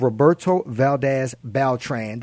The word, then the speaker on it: Roberto Valdez-Beltran: And I came across an awesome guy by the name of Roberto Valdez Beltran.